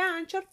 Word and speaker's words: Anchor: Se escribe Anchor, pero se pronuncia como Anchor.